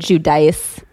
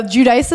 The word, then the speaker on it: Giudice: She gives you like 8 different pronunciations. I think she says she likes to say it Giudice. – No, Giudice is how you say it in America. Giudice is how you say it on TV. And Giudice is how she says it to her family.